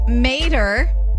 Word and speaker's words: Mater: Lightning McQueen and Mater.